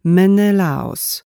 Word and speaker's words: Menelaos: Jej ojciec kiwnął głową, gdy tutor pochwalił Izabellę, mówiąc, jak wspaniale odpowiedziała i dodając, że to imię wymawia się Menelaos.